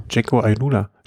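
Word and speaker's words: Jayco AlUla: Dann Team, wie spricht man es aus? Ich weiß gar nicht. – Jaco Alula.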